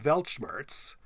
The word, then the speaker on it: Weltschmerz: Last week we talked about Weltschmerz, written with a W, pronounced with a V.